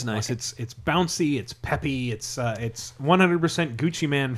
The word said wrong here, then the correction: Gucci Mane: free. So Gucci Mane. – Gucci Mane? – Yeah.